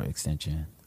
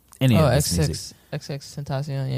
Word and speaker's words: XXXTentacion: Oh, how do you pronounce his name? – XXXTentacion. – Is that really how you pronounce it? – Yeah. – Wow. No, I don't know why I always thought it was an extension. But anyway.